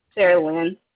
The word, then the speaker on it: Saraland: Is it Sarah Lynn or Sarah Lynn.